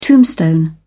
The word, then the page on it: tombstone: British English